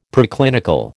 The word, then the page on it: Preclinical: American English